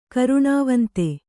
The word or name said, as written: ಕರುಣಾವಂತ